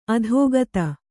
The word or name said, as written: ಅಧಿಗತ